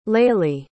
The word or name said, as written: Lailie